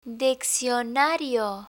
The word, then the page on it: diccionario: Spanish